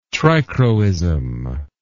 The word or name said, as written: trichroism